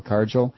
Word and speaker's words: Cargill: We're talking Cargill, or Cargill, how do you say it? C-A-R-G-I-L-L, Cargill. – Cargill, yeah.